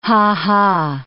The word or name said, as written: ha-ha